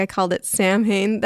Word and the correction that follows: Samhain: That's how it's spelled, but I think it's called Samhain because the Celtic pronunciations of things are all weird.